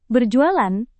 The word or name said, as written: berjualan